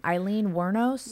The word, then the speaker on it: Aileen Wuornos: So it's only fitting that we cover, how do I pronounce her last name? Eileen Wuornos? – Yeah. – Is that how you say it? – Mm-hmm.